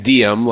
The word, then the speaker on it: Diem: Oh, I say my name is Diem, like Carpe Diem or Per Diem.